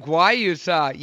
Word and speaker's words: Guayusa: Did I say that right? Guayusa. Yeah, man.